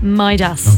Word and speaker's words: Midas: She said it's not as crazy as Bambi, but she worries because everyone that she has told the name to keeps pronouncing it wrong. But we know now it's Midas.